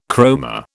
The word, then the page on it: chroma: British English